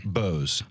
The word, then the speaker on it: Janssen: And how do we say your last name? – Bose. – Bose. Jay Bose.